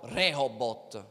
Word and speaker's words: Rehobot: E lui dice, questo pozzo sarà chiamato robot, no robot, sarà chiamato Ribot, come si, si pronuncia, non lo voglio sbagliare, ve lo leggo. Questo posto, sto leggendo dal Genesi 26, sarà chiamato Rehobot, scusate, Rehobot, che significa l'Eterno ci ha messo a largo e noi prospereremo nel paese.